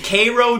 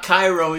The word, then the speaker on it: Cairo: Our Seminole Cup Bowl, we went to Cairo, Georgia. It's not Cairo, even though it's spelled like Cairo.